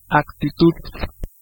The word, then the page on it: mindset: [ˈmaɪnd.ˌsɛt]; American English